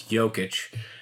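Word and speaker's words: Jokić: And I pronounced his name wrong the first time. It's Jokic.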